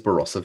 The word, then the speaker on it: Barossa: It isn't long until the two women are found trying to start a new life in South Australia's Barossa Valley.